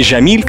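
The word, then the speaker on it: Жамиль (جميل): Египтяне принципиально ее не произносят, они произносят Г почему-то. Да, то есть, например, не Жамиль красиво, а Гамиль. – Гамиль, да.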